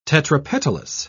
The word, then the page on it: tetrapetalous: British English